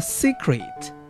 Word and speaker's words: secret: 好，我们再看这个单词当中的第二个字母 E，第二个字母 E 在音标当中会读成一个 a，短音 a，但是其实在美音当中通常就会读成 E，类似于 E 这样的发音 secret secret，然后在英音当中会读成 a secret secret，所以这个地方大家读成 a 或者是 E 都是没有太大关系的。